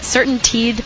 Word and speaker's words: CertainTeed: CertainTeed. I think I'm pronouncing that right. – You are.